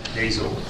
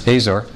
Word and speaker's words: Hazor: Hazor. – You pronounce it Hazor? – Yeah. – Is that what they said? Okay, Hazor.